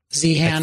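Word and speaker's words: Zeihan: Was it Zion? I forget if that's how you pronounce his name. Peter Zion? Z-han. – I think that's right.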